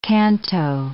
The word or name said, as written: canto